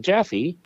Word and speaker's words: Jaffe: So I met Claire and Dina and Betsy Jaffe. – Rhymes with Taffy.